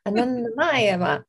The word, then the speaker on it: Anandamayi Ma: Anandamayi Ma yes, it's beautiful.